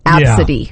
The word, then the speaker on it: Abcde: The name ABCDE pronounced Absidy.